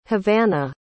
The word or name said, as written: havana